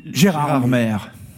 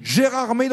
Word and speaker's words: Gérardmer: Gérard Maire. – La prononciation. La prononciation exacte. – Gérard Maire dans les Vosges.